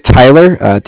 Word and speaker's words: Tyler: My name is Tyler, T-Y-L-E-R, and I'm calling with Cardiff. It's spelled C-A-R-D-I-F-F, as in Frank.